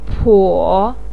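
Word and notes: po3: tone 3

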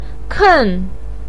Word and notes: ken: 4

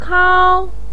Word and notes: kao: 1

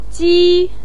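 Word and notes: ji: tone 1

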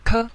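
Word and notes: ke: tone 5

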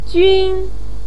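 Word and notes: jun: tone 1

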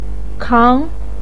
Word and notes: kang: 2

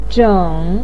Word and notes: zheng: 3

